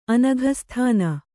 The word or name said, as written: ಅನಘಸ್ಥಾನ